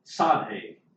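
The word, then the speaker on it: Tsadhe (צדי): And here we begin this refrain that has the Hebrew letter, and it's pronounced different ways depending on who you ask, but Tsadhe is how I learned to pronounce it when I was taking Hebrew, Tsadhe.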